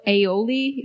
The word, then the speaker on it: aioli: Benny's Chicken and Waffle Sandwich, drizzled with sage maple aioli.